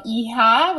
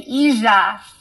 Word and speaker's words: hija: So, and we pronounce our J. So instead of, for example, hija, which would be girl, we say hija.